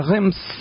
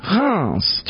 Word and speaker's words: Reims: Encore un exemple à Reims. Reims. Tu vois, j'y arrive pas. Tu vois, voilà. On prononce pas le N.